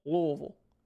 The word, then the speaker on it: Louisville: You have to say Louisville. I believe that's Louisville. Louisville. Like you got to just move your mouth as little as possible. Louisville. – You can't say Louisville.